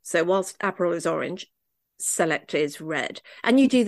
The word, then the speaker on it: Select: which is spelt like select, but pronounced Select.